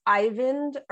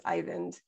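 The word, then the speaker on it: Eyvind: Also really lately been into, and I posted about this on Instagram, I think it's Ivind Earl. I think it's pronounced Ivind.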